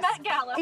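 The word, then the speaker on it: Met Gala: It rhymes with Bet Mala. – Get Mala. – Yes. – Yes. Bet Gala.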